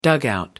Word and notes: dugout: American English

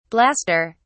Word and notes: blaster: British English

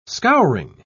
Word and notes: scouring: British English

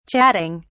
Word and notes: chatting: British English

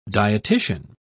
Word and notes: dietician: British English

